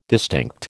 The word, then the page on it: distinct: American English